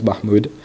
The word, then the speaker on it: Mahmoud: My name is Saif, S-A-I-F. My last name is Mahmoud, M-A-H-M-O-U-D.